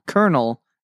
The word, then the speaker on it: colonel: It's pronounced colonel, and it's the highest rank in the military.